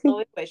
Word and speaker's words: Loewe: Loewe. They're saying it's not Loewe. It's Loewe.